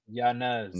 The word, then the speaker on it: Yanez: Yanez. No, no, no. He's issued a correction. He said that it, he pronounces it Yanez.